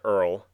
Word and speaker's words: URL: Earl, spelled URL and pronounced Earl, is the robot, and Smitty is the human.